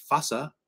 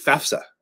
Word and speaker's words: FAFSA: FAFSA, not FASA or FASFA. Some people pronounce it those ways. They're just dumb. It's FAFSA.